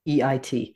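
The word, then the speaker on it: Uskoreit: Uskarite. Sorry, I mispronounce his name all the time. Uskorite. – Sounds good.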